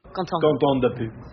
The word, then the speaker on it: Quentin Dupieux: Quentin Dupieux. – So hold on, are we getting it right, ready? Quentin Dupieux.